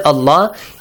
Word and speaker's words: Allah: So, if the letter before the word Allah, it carries a fatha or a dhamma on the top, the word Allah is pronounced how? – Heavily. – Inna Allah, la. What if it carries a kasra below it? – Lightly.